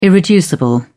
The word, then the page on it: irreducible: British English